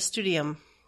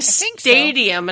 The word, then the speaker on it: Studium: I enjoyed those for what they were in the Crysterium, and I look forward to doing them in the Studium. Am I saying that right, though? Studium? People were saying stadium, and I'm like, no, studium, like students, right? – It's got a U in it.